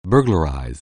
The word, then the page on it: burglarize: anglais